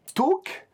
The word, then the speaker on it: Taake: J'espère que je prononce bien, qui a beaucoup participé et qui m'a conseillé deux groupes norvégiens, à savoir Tauk, qui s'écrit T-A-A-K-E, qui est, apparemment était d'abord baptisé Tool, et Tauk, ça signifie brouillard ou brume.